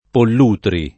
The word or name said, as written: Pollutri